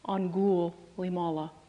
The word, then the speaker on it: Angulimala: And take special note of the ghoul in the middle, Angulimala. I'll tell you why in a moment. It's spelled G-U, but I'm saying it is G-H-O-U-L.